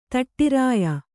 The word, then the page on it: ದಾಢ್ರ್ಯ: dārdhya